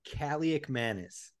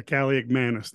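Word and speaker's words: Kaliakmanis: And at quarterback, and I'm Kaliomankis, I'm never going to pronounce that totally right. – I got you. It's Kaliomankis. – Yeah, Kaliomankis. Thank you.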